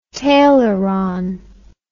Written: taileron

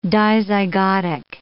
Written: dizygotic

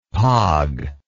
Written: pogge

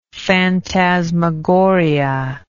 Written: fantasmagorically

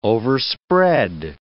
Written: overspread

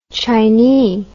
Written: chinee

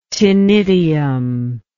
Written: ctenidial

